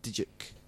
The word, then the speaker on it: Dyck: It's by this guy called Peter Van Dyck or Dyke. I don't know how you pronounce it. – Dyke. – Dyke. – Peter Van... – It's spelt Dyck.